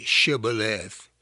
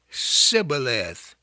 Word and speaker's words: Shibboleth: And they could not say Shibboleth. They would say Sibboleth.